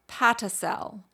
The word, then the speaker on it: Patacel: Now, UK startup Fibe, F-I-B-E, has developed Patacel, and that's spelled P-A-T-A-C-E-L, Patacel, a fiber made from potato waste, which it believes is well-positioned to meet this challenge.